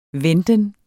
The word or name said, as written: venten